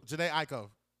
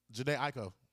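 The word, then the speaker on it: Jhené Aiko: Janae Aiko. – Janae who? – Aiko. – No, you got to pronounce both. – Janae. – Both vowels. – Janae Aiko. – No, you have to say both vowels.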